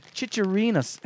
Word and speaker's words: chicharrones: It was a chicharrones. How do you say that? – Chicharrones.